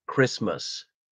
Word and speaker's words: Christmas: Well, Christmas. But it's always spelled Christmas. We never pronounce the T for some reason.